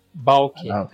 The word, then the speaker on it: Baalke: O Trent Baalke, que chegou agora. – Baalke. – É difícil, assim, cada lugar é uma pronúncia.